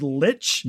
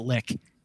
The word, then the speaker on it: lich: the monster is a lich, although they pronounce it lick. – Okay. – And it took me half the episode to realize what they were saying. And I was like, that's just a really unfortunate pronunciation.